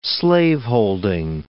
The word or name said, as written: slaveholding